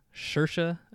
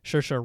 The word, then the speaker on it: Saoirse: The actress that played Lady Bird, I believe you say her name, Saoirse. I believe that's how you say her first name. Saoirse Ronan.